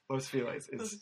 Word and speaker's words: Los Feliz: Like, anytime, I don't know if, Los Feliz, how do you say it? Los Feliz? – Los Feliz. Yeah. But that's not how it's said in Spanish. So right, yeah. – It's a dumb LA version.